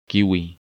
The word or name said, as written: Kiwi